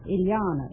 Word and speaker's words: Ilyana: Ilyana. It rhymes with manana.